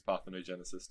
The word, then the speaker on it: parthenogenesis: Yeah, and I mean, the inbreeding would have still been a problem even if they had used parthenogenesis to reproduce.